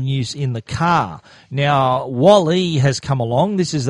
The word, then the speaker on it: Walle: Now, the original Wally, and that's spelt Wall, W-A-L-L-E, so that's how I'm pronouncing it, Wally, was a mounting system for the iPad.